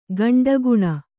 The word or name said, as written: ಕೆಂಡಗಣ್ಣ